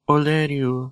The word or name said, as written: oleriu